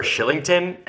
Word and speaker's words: Kylington: So my one surprise for them, obviously Markstrom is the biggest one, but Oliver Shillington, and his name is spelled Kylington, so it grinds my fucking gears every time he plays and every time people say his name, but he's been unbelievable.